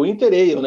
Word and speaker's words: Winter Ale: Weibier, você que quer, assim como eu, tomar uma beira maravilhosa, cara, experimentar essa Winter Ale aqui, espero que eu esteja falando certo, porque não deve ser o Winter Ale, né? É o Winter Ale, né? Igual aquela Pale Ale, né? Que se escreve Pale Ale, né? Então é Pale Ale. Então essa daqui é uma Winter Ale.